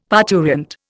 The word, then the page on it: parturient: Indian